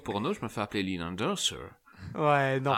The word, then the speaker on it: Leland Orser: Mais je le prononce mal, c'est Leland Orser, Voilà.